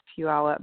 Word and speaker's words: Puyallup: And to Penny, I think it's Salm from Puyallup, Washington. And by the way, Charlie, you pronounce Puyallup much better than I did when I first moved here 22 years ago.